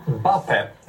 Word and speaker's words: Mbappé: Ja, Mbappé, rätt uttal och så, så drar han igång en ny mening och det, där, klipps ju, klipps ju klippet.